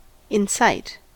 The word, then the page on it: incite: American English